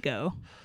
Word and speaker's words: Daigo: pronounce it Daigo.